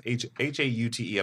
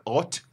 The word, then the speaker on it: haute: I've been a fan of your take on what a, how do you pronounce haute? I'm not sophisticated enough. Is it haute? You have to be French enough to say. – No, you don't. You said it beautifully.